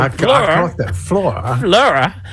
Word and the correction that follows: fleur de sel: Actually, it's French, y'all. Fleur. I hope I'm saying it. I'm not butchering this. Fleur de Sel, which is Fleur.